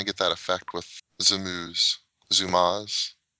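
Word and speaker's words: Zoomooz: And then the really interesting one I thought was one, I'm not even sure how you pronounce it, but it's Zoomooze, like Zoom and then O-O-Z, Zoomooze.js, that will basically allow you to zoom to any element in the DOM.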